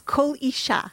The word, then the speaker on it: Kol Isha: Kol Isha. – Kol Isha. The L is silent? Or is that? – No, Kol, and then it's Isha. So Isha. The accent is on the last syllable.